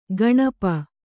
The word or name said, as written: ಕೌಣಪ